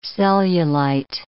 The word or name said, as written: cellulite